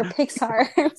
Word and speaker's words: Pixar: But anyway, one night we were playing Would You Rather, and she said, do you prefer Denny or Pickar? – I didn't even say it like that. I said Disney. Pickar. Or Pickar. Or Pixar.